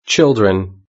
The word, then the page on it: children: British English